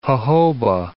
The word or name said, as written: jojoba